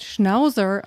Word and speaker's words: Schnauzer: Ich habe äh, Sauerkraut, Schnauzer, was man aber im Englischen mit dem weichen Z Schnauzer ausspricht. Schnauzer? Und Schnauzer.